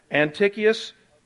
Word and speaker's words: Antiochus: And all you got to do is just write Antioch, A-N-T-I-O-C-H, U-S, although it's pronounced Antiochus, Epiphanes, E-P-I-P-H-N-E-S.